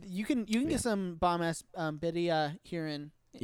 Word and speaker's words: birria: And this is totally a ripoff of the how do you pronounce it? Birria? – Birria. – Birria. Thank you.